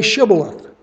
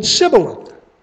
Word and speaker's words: Shibboleth: When he said, No, they said to him, Then say Shibboleth. And he said Sibboleth, for he could not pronounce it right.